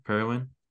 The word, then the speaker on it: Parowan: Parowan? – Yeah.